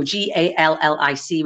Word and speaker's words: Gaelic: a You know, languages would obviously move and be introduced, but Scottish people are really proud of Gaelic, and that we do pronounce it Gaelic, so almost like you know, Gallic would be the Gaelic.